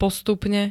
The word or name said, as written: postupne